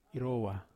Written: rowa

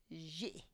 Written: zhi

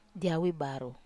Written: awi baru

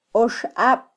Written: oxab

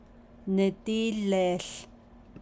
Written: nidííleeł

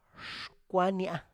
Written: xkwania

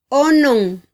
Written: onon

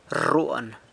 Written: rruan

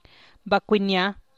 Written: bakuinia’

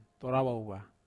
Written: torawawa

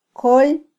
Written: kol

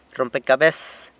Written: rrompecabes

